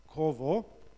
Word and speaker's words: kavah: The Hebrew word is kavah, pronounced korvo.